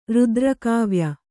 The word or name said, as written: ರುದ್ರಕಾವ್ಯ